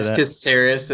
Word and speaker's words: Caceres: Did I say that right? – Caceres. It's the Americanized way of saying it, but I'm used to it.